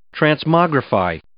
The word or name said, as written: transmogrify